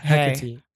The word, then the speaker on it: Hecate: Hecate is the real pronunciation is what he Hecate.